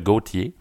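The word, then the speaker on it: Gotye: En tout cas, la, chanson Somebody That I Used to Know du chanteur, du chanteur belge Gauthier, oui, c'est comme ça que ça se prononce, même si ça s'écrit tout croche.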